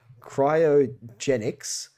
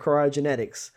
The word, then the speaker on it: cryogenics: I know so little about this mission that I hadn't even said the name correctly until just now. – well done. – I was calling it cryogenetics, which is not correct.